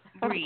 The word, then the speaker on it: Brye: It's done by, I think you pronounced her name Bree, Betsy Bree. It's not spelled like the cheese, but, you know, B-R-Y-E, Bree.